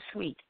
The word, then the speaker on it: Suite: I am Sweet, S-U-I-T-E. Sweet Franchon is my name, spelled like a hotel suite, but it sounds so sweet like candy, S-U-I-T-E-F-R-A-N-C-H-O-N.